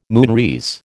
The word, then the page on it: moonrise: American English